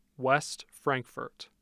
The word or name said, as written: West Frankfort